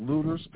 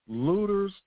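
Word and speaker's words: Lueders: And the text was about a town in Texas called Looters, Texas. That's spelled L-U-E-D-E-R-S, L-U-E-D-E-R-S, Looters, Texas.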